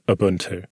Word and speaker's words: Ubuntu: There is no Y at the beginning of Ubuntu.